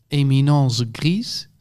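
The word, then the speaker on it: éminence grise: En het schijnt dat de Eminence Grise, zeg ik dat goed? – Ja, mag ook in het Frans, hè, want hij zit in Frankrijk.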